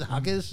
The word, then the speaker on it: Jaquez: Did I pronounce it Hawkes? – Yeah.